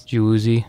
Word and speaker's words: Gueuze: G-U-E-U-Z-E. – Yes. – Goo-E-U-Z is how I would probably pronounce that. – If you want to do it phonetically, yes. – Goose works better than that. So the Jews, if you want to go soft.